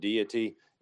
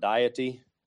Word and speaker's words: deity: So when you hear people talk about the deity of Christ or you see the word deity, it's not, notice how I pronounce that, it's deity, it's not diety. It's not a diet.